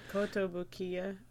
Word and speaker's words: Kotobukiya: This is Kotobukiya, okay. I think. Something. I think that's how you pronounce it. – Kotobukiya.